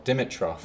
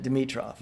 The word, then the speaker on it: Dimitroff: But but long and short of it is I laugh because my brother, who's older than I am, who should know better, pronounces it Dimitroff. – Right, which is kind of an Americanized. – And my response has always been, because I'm pescatarian, there's no meat in Dimitroff.